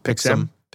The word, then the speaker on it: Pixm: Speaking of looking things up, I had to look up how to pronounce Pixem. I didn't know if it was Pixum or I would have said Pixum. – Pixum. Yeah, that's Pixum. Pixum.